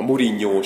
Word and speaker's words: Mourinho: meg, meg a, a Mourinho sem Mourinho. Mourinho-nak. Igen. Ó, ó, a portugálok úgy ejtik.